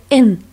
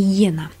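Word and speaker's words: эн (円): Японцы название своих денег произносят как «эн», а не «йена». Когда-то иероглифы, обозначающие деньги, произносились японцами как «вен».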